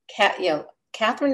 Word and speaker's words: Kathryn: Kathryn is spelled many different ways. K-A-T-H-R-Y-N. – Z-O-X.